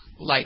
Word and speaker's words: Laich: Julianne Hough has filed for divorce from Brooks Laich, is it? Is that how you pronounce his name? – Brooks Laich. – Yeah.